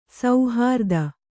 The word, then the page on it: ಸುಹೃದ: suhřda